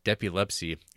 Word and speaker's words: depilepsy: And from last week, the correct pronunciation of depilepsy is literally just epilepsy with a D in front, and yes, was coined because Frank, who isn't Jay Cheel, is a bit of a Johnny Depp apologist in Tim Burton.